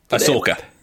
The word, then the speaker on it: Ahsoka: Ahsoka, is that how you pronounce it? – Ahsoka. – Ahsoka, I do apologize.